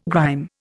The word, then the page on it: grime: Indian